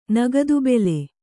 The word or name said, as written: ನಗದುಬೆಲೆ